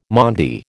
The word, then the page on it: Maundy: American English